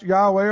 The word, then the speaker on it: Yahweh: The Hebrew name, pronounced Yahweh or Jehovah, means Lord.